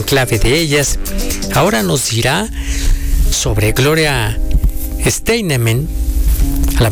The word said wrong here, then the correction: Steinem: Este jueves se confirmó la muerte de Gloria Steinem, periodista, escritora y una de las figuras más influyentes del movimiento feminista contemporáneo.